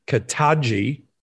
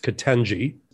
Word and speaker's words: Ketanji: he refuses to pronounce her name properly, just as he did with Kamala Harris, he calls her Ketanji. It's Ketanji.